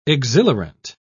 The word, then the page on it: exhilarant: British English